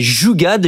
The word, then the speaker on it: jugaad: C'est jugad, J-U-G-A-A-D, qui est un mot hindi qui signifie le fait de trouver des solutions simples, ingénieuses et peu coûteuses à des problèmes qui sont très complexes.